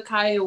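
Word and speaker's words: Zakiyyah: This is Akaya Whitfield.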